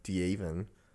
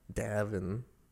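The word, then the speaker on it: Deavan: It's D E A V A N, Davin, Davin. A lot of people mispronounce. – It's Davin. That's how it's spelled. My name is Devan. Not Devin.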